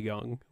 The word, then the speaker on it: Jung: Spelled like Carl Young, like J-U-N-G.